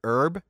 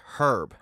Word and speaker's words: herb: I know, and I don't know if this is ubiquitous across most native English speakers in the British Isles, but if I'm correct, it's pronounced herb. – Herb, right. – They pronounce the H.